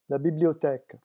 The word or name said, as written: la bibliotheque